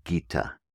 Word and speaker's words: Gita: Bhagavad is spelled B-H-A-G-A-V-A-D. Bhagavad, Bhagavad, Bhagavad. Second word, Gita, Gita.